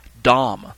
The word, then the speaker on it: dam: The next word is dam. Spelled D-A-M, but pronounced as if it's D-O-M.